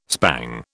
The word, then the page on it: spang: British English